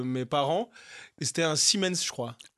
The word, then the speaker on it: Siemens: C'est à l'allemande, Siemens.